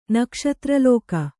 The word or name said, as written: ನಕ್ಷತ್ರಲೋಕ